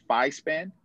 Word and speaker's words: FISPAN: FISPAN? – Yeah. – Yes. – Great. All right. So I don't want to mess it up. So give everyone like the hierarching kind of perspective of what FISPAN, you know, what do you guys do?